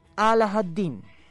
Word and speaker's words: Alahaddin: Durante la redacción, o la relación, más bien vamos a decir, de este cuento, vamos a estar llamándolo Aladín, porque es la pronunciación más cercana al árabe original, que es Alahaddin.